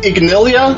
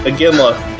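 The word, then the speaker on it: Iginla: Jerome Ignilia. – Aginla.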